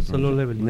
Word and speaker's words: Solo Leveling: Solo Leveling.